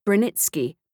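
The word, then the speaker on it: Branicki: In Polish names, such as Brunicki, I have left the name in its more polonized form, pronounced Brunitsky.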